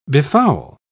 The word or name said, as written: befoul